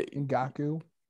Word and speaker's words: Ngakoue: Yeah, Ngakoue.